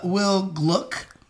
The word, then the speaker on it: Gluck: So, Will Gluck.